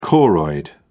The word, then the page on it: choroid: British English